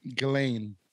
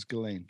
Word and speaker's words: Ghislaine: Ghislaine. – Is that how it's pronounced? – Yeah, she's French. It's Ghislaine.